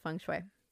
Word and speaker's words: feng shui: Yes, I know it's pronounced feng shui.